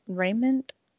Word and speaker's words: raiment: Oryx wears a remnant of worm silk. Sorry. Let me say that again. And how do I pronounce that? Raiment.